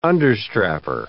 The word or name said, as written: understrapper